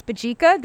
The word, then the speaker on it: Mbadika: If you look at Mbadika, that's Mbadika. The M is silent, so don't doubt yourself. If there's an M, that's Mbadika.